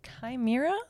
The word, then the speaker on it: chimera: This week we will take a closer look at the chimera? Question yeah. mark? Yeah, you said it right.